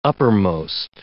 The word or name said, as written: uppermost